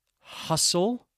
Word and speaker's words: hustle: Okay, so what are some other common words with a silent T? How about ballet? B-A-L-L-E-T. Hustle? H-U-S-T-L-E. And its friend, bustle? B-U-S-T-L-E.